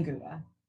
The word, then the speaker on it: Gouda: Gouda is, it's Dutch, so the G sounds like ha.